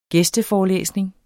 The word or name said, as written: gæsteforelæsning